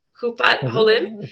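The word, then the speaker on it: Kupat Holim: Hupat Holim? – Yeah, it's an HMO, yeah. – Did I say it correctly? – Yeah, you said it correctly.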